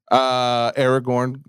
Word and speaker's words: Aragorn: Aragorn.